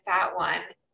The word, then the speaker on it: Fatone: And then Rusty says, It's like they're a boy band and I'm the fat one. To which Casey says, It's pronounced fatone.